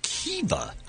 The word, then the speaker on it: Keeva: Kiva? – K-E-E-V-A.